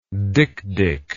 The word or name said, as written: dikdik